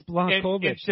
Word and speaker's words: Błachowicz: Yes, right. It's Błachowicz.